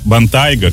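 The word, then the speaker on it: Bentayga: Вот Бентли Бантьяго и Бантайго. – Бентайго. – Бентайго.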